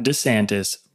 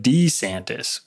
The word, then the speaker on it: DeSantis: Today, we are going to be talking about Ron DeSantis. Yes, Ron DeSantis. I learned yesterday during his presidential announcement, it's not DeSantis, but DeSantis, which is how he says it.